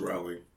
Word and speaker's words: Rowling: Rowling, I can never say her name right. – It's Rowling.